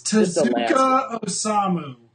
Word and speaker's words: Tezuka Osamu: It's Tezuka Osamu.